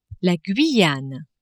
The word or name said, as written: Guyane